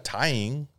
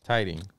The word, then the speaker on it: tithing: Tying. – Tithing. – Tithing. – Tithing, yeah. It's a TH. I was saying it right.